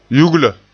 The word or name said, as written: yugla